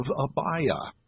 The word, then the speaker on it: Abia: In verse 5, a certain priest named Zechariah of the division or the course of Abia, as would be pronounced in Hebrew.